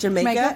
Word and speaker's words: jamaica: It's spelled like Jamaica, and they pronounce it Jamaica.